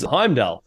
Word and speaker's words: Heimdal: Well, speaking of album names, Heimdal.